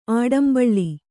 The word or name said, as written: ಆಡಂಬಳ್ಳಿ